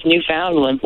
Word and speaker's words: Newfoundland: Now, am I saying that right? Because in Canada, they say Newfoundland. What do they say in New Jersey? – It is Newfoundland. – Newfoundland. All right.